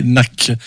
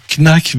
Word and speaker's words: Knack: On est toujours en 1979 et puis il s'agit d'un groupe que vous connaissez bien puisque c'est les Knack. Alors, à la française, on dit Knack, mais normalement ça se prononce Knack sur le premier album Get the Knack.